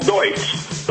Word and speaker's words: Deutz: It's called Deutz, but it's spelled D-E-U-T-Z.